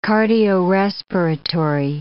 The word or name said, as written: cardiorespiratory